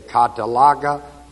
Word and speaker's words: katallage: Another word is the word K-A-T-A-L-L-A-G-E, long E. And another word is al-lasso, A-L-L-A-S-S-O. It just builds up from alos to alasso to catalaga to catalasso to apo catalasso, which we have.